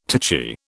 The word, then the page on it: titchy: British English